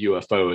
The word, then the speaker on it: UFO: Well, that's because that's the way the Brits say UFO is UFO.